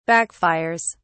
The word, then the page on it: backfires: British English